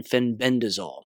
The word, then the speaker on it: fenbendazole: It's the correct pronunciation, fenbendazole.